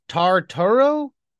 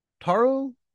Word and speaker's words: Tartaro: It's pronounced Tartaro. Tartaro. The Tartaros are one-eyed ogre shepherds roaming the wild hills and mountains of the Pyrenees.